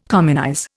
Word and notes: communize: Indian

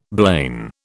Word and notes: blain: British English

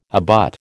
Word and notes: abut: American English

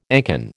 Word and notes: ancon: American English

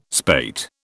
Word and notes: spate: British English

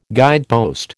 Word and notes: guidepost: American English

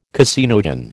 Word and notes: caseinogen: American English

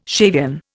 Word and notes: Shavian: Indian

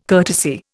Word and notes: courtesy: Indian

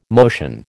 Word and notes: motion: American English

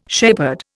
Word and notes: shepherd: Indian

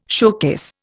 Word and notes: showcase: Indian

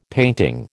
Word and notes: painting: American English

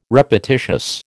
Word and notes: repetitious: American English

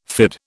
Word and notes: fyrd: British English

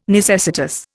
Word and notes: necessitous: Indian

